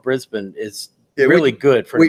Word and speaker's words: Brisbane: which I know is pronounced Brisbane because of you. – Brisbane. – It looked like Brisbane to me.